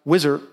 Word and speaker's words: Wishart: But I was introduced to a guy that I had never heard of, a guy named George Wishart. I've also heard his name pronounced Wizard.